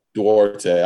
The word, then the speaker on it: Duarte: Duarte.